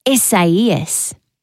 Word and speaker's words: Isaias: It's Spanish, pronounced Isaias. You spell it I-S-A-I-A-S. Oh, that's interesting, isn't it? Just one letter removed from Isaiah.